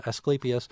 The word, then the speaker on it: Asclepias: Is it Asclepias? – Yes. – Asclepias. – Asclepias. Okay, right. – So I was leaving out a syllable there, Asclepias.